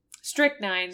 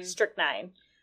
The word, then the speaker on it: strychnine: He and Shamsphere was poisoned by, how do you say this poison again? – Strychnine. – Strychnine.